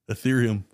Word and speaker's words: Ethereum: He said, "Okay, so what we basically do here is we make money daily using Ethereum technique." – Ethereum? – Ethereum? – Oh, the crypto? – Yeah. – Yeah, Ethereum.